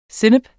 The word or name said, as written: sennep